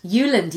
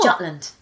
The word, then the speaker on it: Jutland: Is that how you say it? – Jutland, yes. Oh. Not Jutland. Yeah.